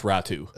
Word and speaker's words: Räty: Yeah, it's spelt Ratty, but it's pronounced Ratu.